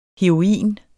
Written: heroin

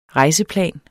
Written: rejseplan

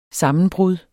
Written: sammenbrud

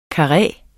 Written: KARREERNE

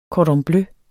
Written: cordon bleu

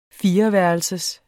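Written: fireværelsers

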